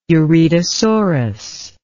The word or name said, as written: uredosorus